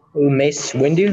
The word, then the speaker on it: Mace Windu: Why is Mace Windu, that's how you pronounce his name, right? – Yeah.